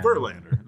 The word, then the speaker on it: Verlander: Now you're just overemphasizing all of the wrong Verlander. Syllables right now. – Verlander. That's it. It's easy.